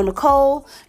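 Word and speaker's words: Necole: My name is Michelle Nicole. Nicole is spelled with the E.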